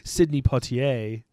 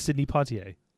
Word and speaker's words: Sidney Poitier: I've never heard someone say Sidney Poitier. It's Sidney Poitier.